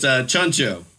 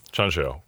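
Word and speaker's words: Tjahjanto: It's Chancho. – Oh, cool. – Yeah, it's a CH sound with the T and the J there.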